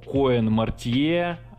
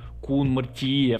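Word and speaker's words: Koen Mortier: Во втором выпуске я хочу поговорить о таком режиссере, как Коэн Мартье а, на кинопоиске, хотя правильнее будет Кун Мартье в соответствии с его корректным произношением.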